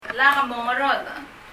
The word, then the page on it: Lak mongerodech: lʌ(k) mɔŋərɔðə?